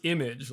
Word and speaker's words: Imig: It's pronounced image, like image. Like oh, like a picture.